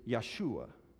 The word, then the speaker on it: Yeshua: Jesus actually is the Greek form of the Hebrew name Joshua. Or if we were to pronounce it in Hebrew, it would be Yeshua.